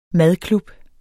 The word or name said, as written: madklub